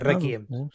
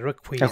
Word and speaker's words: Requiem: No, porque es inglés. Requiem. No se dice ah, Requiem, se dice Requiem.